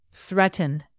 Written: threaten

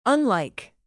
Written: unlike

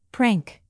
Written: prank

